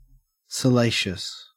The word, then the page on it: salacious: Australian English